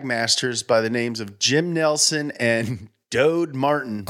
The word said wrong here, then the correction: Dode: probably Dodie Martin. – Oh, my bad. My bad, it's pronounced Doty.